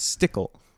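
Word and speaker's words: Stickell: Key operatives, Hunt, Ethan, Stickel, Luther, Dunn, Benji. – It's Stickle. – Carter. I'm sorry. The way they spell it, they shouldn't have spelled it that way.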